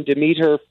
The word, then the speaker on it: Demeter: We landed on Demeter for this film.